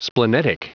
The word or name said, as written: splenetic